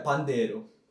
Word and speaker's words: pandeiro: Yeah, pandeiro.